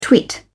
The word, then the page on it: twit: British English